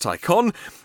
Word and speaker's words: Taycan: Porsche say they're going to release their first electric model, the Taycan, which is spelt Taycan but pronounced Taycan, and used to be called the Mission E, great, that's clear, in 2019 sometime.